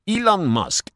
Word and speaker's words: Elon: Elon Musk. – Ok. – Tú dijiste Aileen Musk.